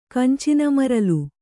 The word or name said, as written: ಕಂಚಿನಮರಲು